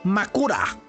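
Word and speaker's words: Makura (枕): Se dice Makura.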